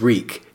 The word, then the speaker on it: Reek: My name is Reek. It rhymes with bleak.